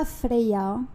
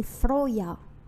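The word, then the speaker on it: Freyja: C'est pas Freya, c'est pas Freya, c'est Froya, etc.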